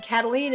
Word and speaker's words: Kataline: Karen is spelled the normal way, no goofy Y's or Q's, K-A-R-E-N, and Cataline is spelled K-A-T-A-L-I-N-E.